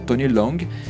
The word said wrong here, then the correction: Tony Leung: e eu falei que é em francês agora, que tem nada a ver, e, e o Andy Lau. Inclusive, o Tony Leung vai estar tá no, no, no filme da Marvel ano que vem, né?